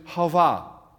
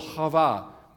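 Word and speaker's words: Hava (חוה): And we actually miss this because Eve doesn't actually capture the Hebrew pronunciation, which is Hava. You get that throat, Hava, is the actual name of Eve in Hebrew.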